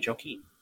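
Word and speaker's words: Joachim: and they actually have a different pronunciation of it, and it's Joakim. But no, I just tell them, you know, call me Joakim. So So yeah, that's how I tell people to pronounce it.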